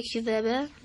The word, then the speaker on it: كِذَّابًا: کذبا. – بله زالش تشدید داره، دقت کنید. – و کذبو بیاتنا کذبا.